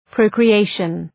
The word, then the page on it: procreation: ,prəʋkrı'eıʃən